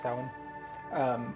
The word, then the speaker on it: Samhain: So Halloween is based on Samhain. Mm-hmm. Am I saying that right? – Yes. – Samhain.